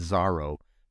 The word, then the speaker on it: Zorro: But they pronounced it Zaro, but it's spelled Zorro like the Yeah.